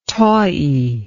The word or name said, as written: tawie